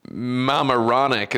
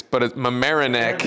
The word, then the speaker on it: Mamaroneck: I always want to say Mamaroneck. It's Mamaroneck, New York.